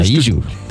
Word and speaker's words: Kaiju: First beer is called Kaiju Imperial Rice Lager. Am I pronouncing that correctly? – Sure. Kaiju.